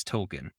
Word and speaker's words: Tolkien: I learned early on that it's Tolkien, and that's just how I say it.